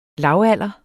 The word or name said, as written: lavalder